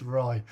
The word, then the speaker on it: Rye: And he was like, oh, I thought it was the RY stand. I was like, no, it's Rye.